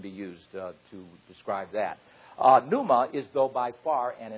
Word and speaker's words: pneuma: One, pneuma. Phonetically, it's P-N-Y-O-O-M-A-H, pneuma. And the other one is phantasma.